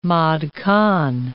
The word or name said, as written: mod con